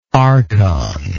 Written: archonship

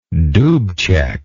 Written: dubcek

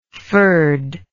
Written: fyrd

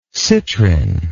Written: citrin

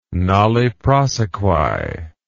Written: nolle prosequi

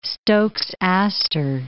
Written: Stokes' aster

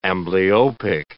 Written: amblyopic

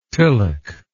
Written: tilak

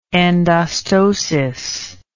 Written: endostosis

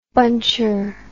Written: buncher

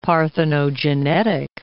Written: parthenogenetic

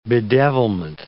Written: bedevilment

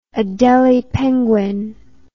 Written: adelie penguin

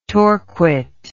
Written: torquate

